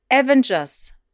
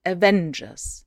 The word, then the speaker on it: Avengers: Und dann noch Fun Fact: Avengers kommt von Avenge, zu Deutsch rächen. – Also liegt die Betonung nicht auf dem großen A, also Avengers.